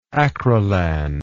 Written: acrilan

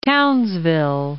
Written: Townsville

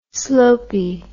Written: slopy

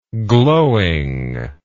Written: glowing

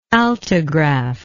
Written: altigraph

